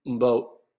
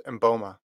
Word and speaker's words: Mboma: Christine Momba. I said her name wrong. Christine Mboma. Mboma? How do I say her last name? – Yeah, I think it's Mboma.